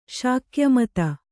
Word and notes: ಶಾಕ್ಯಮತ: śakya mata

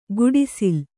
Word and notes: ಗುಡಿಸಿಲ್: guḍisil

